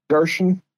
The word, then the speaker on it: Gersheon: Gershon.